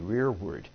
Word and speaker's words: rereward: But it's spelled R-E-R-E-W-A-R-D, rear word.